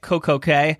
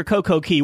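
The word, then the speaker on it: CocoCay: It went to Nassau in their perfect day at Coco Cay. James, is it Coco Cay or Coco Key? What are you calling it? – I call it Coco Cay, but I hear it pronounced both ways.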